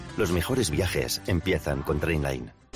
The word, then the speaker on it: Trainline: Se pronuncia Trainline. Descarga la app de Trainline y ahorra comparando miles de trenes y tarifas.